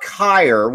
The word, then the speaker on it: Kier: Okay, now, can we talk about Kier, which also rhymes with Grier?